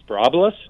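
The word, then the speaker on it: Sporobolus: What is it, Sprobolus? – Yes. And uh-huh. – Can you spell that? – S-P-O-R-O-B.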